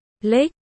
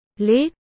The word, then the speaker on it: lứt: Trong phương ngôn tiếng Việt Bắc bộ thì lứt và lức có cách đọc khác nhau. Lứt được đọc là lít. Lức, được đọc là lích, không thể thay thế cho nhau.